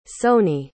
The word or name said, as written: Sony